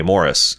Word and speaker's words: Maurice: Based on the pronunciation guide, Maurice Picard uses the English favored pronunciation of the name Maurice, a.k.a. Morris.